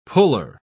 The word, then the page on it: puller: British English